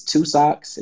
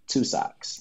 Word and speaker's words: Two Sockz: So my Instagram name is Two Socks. It's spelled TWO, then underscore SOCKZ. Two Socks.